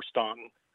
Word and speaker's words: Staunton: You can say Staunton or Staunton. It's pronounced both ways.